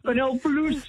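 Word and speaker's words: Canal+: I hope I'm saying it correctly. – Canal Plus. – Correct my French. Canal Plus.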